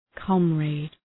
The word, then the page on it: comrade: {‘kɒmræd}